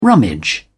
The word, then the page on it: rummage: British English